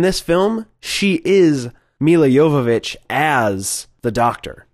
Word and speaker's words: Jovovich: It's Mila Jovovich. – Mila Jovovich.